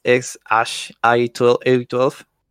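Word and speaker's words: X Æ A-12: O sea, se, se escribe como que ex A E A 12, que ese fue como que el primer nombre que le dio, que se pronuncia como que es ex Ash i 12 eh, es algo así, ya saben, es una locura que tiene lo más como siempre.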